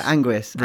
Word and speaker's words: Anguis: Uh, how do you say it? Ang- Angius? Ang- Ang- Anguis? Ang- I can't. I can't. – Oh read, yeah, Anguis.